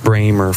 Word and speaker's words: Brahma: The Brahma, pronounced Bramer for as long as I remember, stands smoky-hided and ahead above the rest.